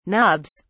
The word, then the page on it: nobs: British English